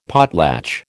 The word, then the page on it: potlatch: American English